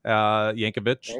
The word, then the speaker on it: Yankovic: In high school, I started making spoof songs, you know, in the vein of Weird Al Yankovic.